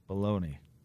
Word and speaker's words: bologna: How do you say the word B-O-L-O-G-N-A? – Baloney. – Why is it not Bologna? – Because it's pronounced baloney. – I thought baloney was B-A-L-O-N-E-Y.